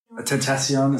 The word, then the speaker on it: Tentacion: Tentacion is how you pronounce it.